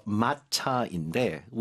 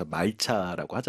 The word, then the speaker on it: 말차: 영어권에서 이제 통용되는 단어는 이제 일본식으로 읽어서 마차인데 우리는 이제 조선시대 이제 표기법과 그 우리나라 발음법을 이용해서 말차라고 하잖아요.